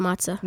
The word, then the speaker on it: matzah: Do you eat that cornmeal with matzah? Mm-hmm. Is that how you say it? – Yeah, it's pronounced matzah.